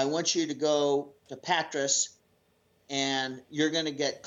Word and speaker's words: Patras: Well, the next day we got up and we were sailing along, and we came up to Patras there. Is that how you pronounce it? – Mm-hmm. Yeah, that's how I say it. Yeah. – Yeah. Patras.